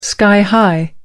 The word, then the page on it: sky high: American English